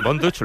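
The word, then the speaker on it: Von Dutch: Así que, Bonduch, ¿lo he pronunciado bien? – Sí, muy bien.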